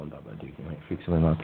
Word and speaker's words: Babadook: It's Babadoc. – What? – Is that how you pronounce it? – No. Oh, my God, that's terrible. – How you pronounce it? – Babadook. Babadook, it's been evolving to Babakook.